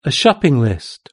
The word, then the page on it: a shopping list: British English